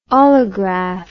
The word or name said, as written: olograph